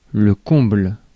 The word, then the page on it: comble: /kɔ̃bl/